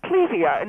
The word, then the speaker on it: Clivia: And it's pronounced Clivia.